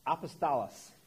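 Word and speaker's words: Apostolos (ἀπόστολος): It is the Greek word apostas. I didn't say that right. I'm going to blame that on breakfast. Apostolos.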